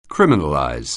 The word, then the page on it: criminalize: anglais